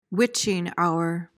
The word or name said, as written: witching hour